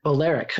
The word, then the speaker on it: Balearic: Balearic. – Balearic.